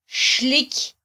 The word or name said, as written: xliky